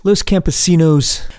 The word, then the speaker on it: Los Campesinos: Los Campesinos.